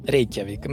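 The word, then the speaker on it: Reykjavík: Так все-таки какое ударение, ты знаешь? – Я думаю, Рейкьявик. – Правильно. Рейкьявик. Мы, мы говорим, я Епи и Рейкьявик, если по-исландски.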